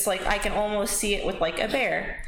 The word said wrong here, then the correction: beer: Not a bear. – Not like the aspirin. A beer. – A beer. Oh, mispronunciation there. – Beer.